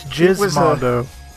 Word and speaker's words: Gizmondo: I just use Gizmondo as a sex move, but um, no, that's Gizmondo. – oh, I've been mispronouncing it this whole time. – it's a soft G, John.